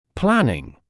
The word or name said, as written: planning